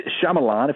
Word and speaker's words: Shyamalan: Night Shyamalan, if I'm saying his name correctly.